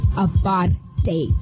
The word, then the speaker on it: apartheid: And just to be frank, it's not apartheid, guys. It's apartheid. Okay, y'all have to say it right because I am, yes, I'm proudly South African, so you'll have to say it right.